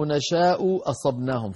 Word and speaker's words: نَشَاءُ أَصَبْنَاهُمْ: أن لو نشاء أصبناهم في الآية 100 أن لو نشاء أصبناهم، هنا التقى في النطق أيها الإخوة همزتان، والهمزة الواحدة ثقيلة، فما بالك إذ اجتمع همزتان صار بيانهما آكد، هكذا أَلَّوْ نَشَاءُ أَصَبْنَاهُمْ، فنبين الهمزة الأولى ونبين الهمزة الثانية.